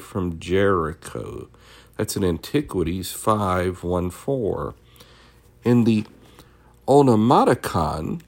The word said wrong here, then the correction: Onomasticon: let me see if I'm pronouncing that right, Onomasticon, Eusebius locates Gilgal at about the second milestone from Jericho.